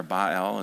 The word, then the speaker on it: Baal: You know that they began to offer their children as sacrifices to the god Baal, or Baal is actually how it's pronounced.